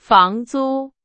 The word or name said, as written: 房租